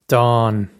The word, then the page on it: dán: dawn; Irish